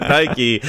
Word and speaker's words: Nike: Most everyone pronounces it Nike. So I guess UK just, like, or people in the UK just pronounce things wrong.